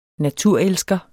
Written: naturelsker

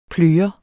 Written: pløret

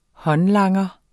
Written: håndlanger

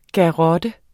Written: garrotte

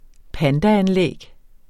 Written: pandaanlæg

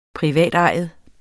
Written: privatejet

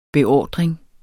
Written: beordring

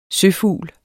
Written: søfugl

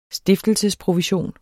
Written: stiftelsesprovision

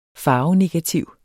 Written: farvenegativ

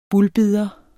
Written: bulbider

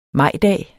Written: majdag

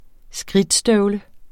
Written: skridtstøvle

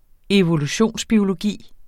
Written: evolutionsbiologi

